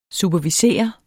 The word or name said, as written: superviser